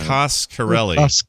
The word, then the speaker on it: Coscarelli: Casacarelli? Costanelli, is that how you say it? – How do you say it? Coscarelli. – Coscarelli.